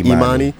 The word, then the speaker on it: Imani: Am I pronouncing that correctly? – Imani. – Uh-huh. Spelled yeah. I-M-A-N-I. – Yes. – Mm-hmm. Imani Baptist mm-hmm.